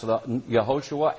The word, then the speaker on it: Yehoshua: In Hebrew, it's Yeshua or Yehoshua. Before the Babylonian captivity, it was pronounced Yehoshua.